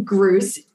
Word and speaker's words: Gruss: So while my last name, Gruse, it rhymes with Seuss, right? It's spelled differently.